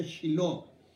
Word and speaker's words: Shiloh: Estábamos platicando respecto a esto y ella me estaba diciendo eh, su experiencia en Silo en, en, Shiloh, se pronuncia Shiloh, este, como SH, no voy a poner Chile, eh, Shiloh.